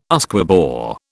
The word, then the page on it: Usquebaugh: British English